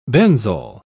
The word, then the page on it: benzol: British English